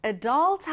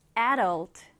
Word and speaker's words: adult: 쌤이 방금 발음했던 것처럼 adult 하셔도 되고요. 앞에 강세를 두어서 adult, adult 이렇게 하셔도 됩니다.